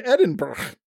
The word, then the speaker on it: Edinburgh: Today, you've gone beyond Edinburgh as you circle back to the harbor.